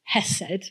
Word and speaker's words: hesed: There's a great Hebrew word, hesed. It's hard for me to say. Doug will say it correctly. – You said it correctly.